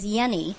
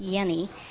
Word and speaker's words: Yenny: The young woman's name is Yenny, like Jenny, but it's pronounced Yenny.